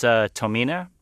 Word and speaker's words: Tomine: And the very first thing I did, actually, was ask how his name, which is spelled T-O-M-I-N-E, is pronounced. – It's Tomina, but Tomina.